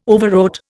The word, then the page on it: Overwrought: Indian